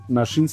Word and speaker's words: Naxin: Nashin se escribe Naxin y es en mazateco que significa venadito.